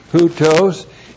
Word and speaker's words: houtos: First of all, houtos. Looks like that in the Greek, H-O-U-T, long O-S, houtos.